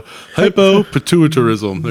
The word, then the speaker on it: hypopituitarism: Estonian psychiatric patient Leanna Klammer, played by Isabella Furman, is a 31-year-old woman with a rare hormonal disease called hypnopituitarism. I probably mispronounced that. It gives her the appearance of a 9-year-old child. And with hypnopituitarism... – It's hypo. Hypopituitarism.